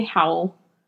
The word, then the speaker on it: Howell: I can barely say Howell.